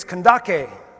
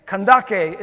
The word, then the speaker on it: Kandake: It's in Greek, it's Kandake, is how you say it. And somebody said, that looks like Candace, so we're going to spell it Candace. Kandake is the name.